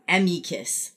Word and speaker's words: amicus: So I guess I actually had a lawyer tell me, a lawyer that I did an amicus brief, it's pronounced amicus, like whatever, brief, which means friend of the court.